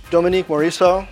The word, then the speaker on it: Dominique Morisseau: Dominique Morisseau.